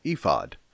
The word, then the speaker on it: ephod: E-P-H-O-D. You have probably grown up pronouncing that ephod. In the Hebrew, it's ephod.